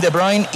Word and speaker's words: De Bruyne: El City, con Ederson, con Walker, con Rubén Díaz, con Akanji, Stones, Rodri, Bernardo Silva, Grealish, Gundogan, Kevin De Bruyne y Haaland.